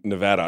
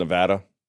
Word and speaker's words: Nevada: Apparently, I've I've always been mispronouncing Nevada. I've been calling it Nevada, and they get real bitter. apparently, it's Nevada.